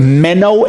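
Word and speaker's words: meno (μένω): That word abide in the Greek language is the Greek word meno, M-E-N-O, with a long O at the end.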